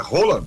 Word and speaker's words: Haaland: É, porque se a gente for pronunciar corretamente o nome do Haaland, como é que normalmente se pronuncia? – É Roland. – É isso mesmo, é Roland.